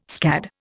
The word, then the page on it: scad: Indian